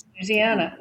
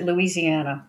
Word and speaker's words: Louisiana: Louisiana. Mm-hmm. Yeah. Although Northwest Louisiana, people pronounce it Louisiana, believe it or not.